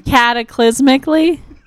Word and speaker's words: cataclysmically: which had a chasmalistically terrible nuclear war thousands of years before the show arrived. – Cataclysmically? – Yes, that's the way to properly pronounce it.